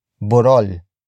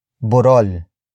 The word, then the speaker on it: burol: It is the location marker that introduces where the act of climbing has happened, and that is Borol. Borol. Borol. Borol is spelled as B-U-R-O-L.